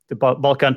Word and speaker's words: Balkan: Am I saying it right? The Balkan Peninsula, right? – Yes.